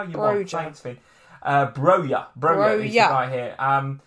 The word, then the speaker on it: Broja: It's pronounced Broja. Okay, Broja.